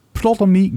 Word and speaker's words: Ptolemy: The Last Days of Ptolemy Gray. – Zeg ik het goed? – Ptolemy. Ptolemy, Ptolemy Gray.